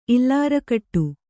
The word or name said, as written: ಇಲ್ಲಾರಕಟ್ಟು